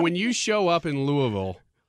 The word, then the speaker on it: Louisville: You have to say it like this. – Louisville. – Yeah, Louisville. It's almost just one sound. Louisville.